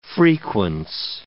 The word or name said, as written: frequence